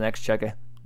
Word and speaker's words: Exchequer: It's spelled out like Exchequer. It's like an R on the end. I mean, like, the Q makes a K sound.